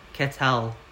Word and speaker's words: que tal: If you want to ask someone how they're going in a cool way, you can say que tal, which is Q-U-E space T-A-L, que tal. In Spanish, they pronounce their Q's like K's for us in English.